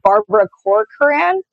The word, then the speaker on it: Barbara Corcoran: I love that woman from Shark Tank, Barbara Corcoran. Is that how you say her name? – Yeah.